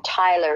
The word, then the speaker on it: Tylre: And I just want everybody to know that my name Tyler is spelled T-Y-L-R-E.